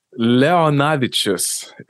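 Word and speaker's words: Leonavičius: So you're ROKAS, your last name is pronounced LEONAVICIUS. – LEONAVICIUS. – I hate that. It's, yeah. Oh, you actually did great.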